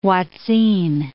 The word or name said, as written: hoatzin